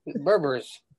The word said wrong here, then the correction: Burberry: Yeah, it's not Berbers. It's Burberry.